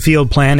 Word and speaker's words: FLDPLN: I believe it's called Field Plan. It's spelled FLDPLN. You know, these like modern artists who hate vowels.